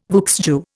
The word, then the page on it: vieux jeu: Indian